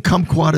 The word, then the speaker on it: kumquat: It is spelled K-U-M-Quat, right? – Yeah. – Kumquat? – Yeah. Kumquat sounds, it's like the country of Guam.